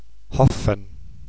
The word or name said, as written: Hopfen